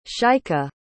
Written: shaikha

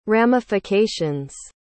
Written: ramifications

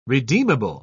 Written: redeemable

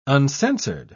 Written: uncensored

